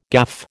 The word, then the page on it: gaffe: American English